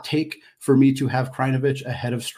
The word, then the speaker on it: Krajnovic: So Luka Krajinovic, we'll get that correct.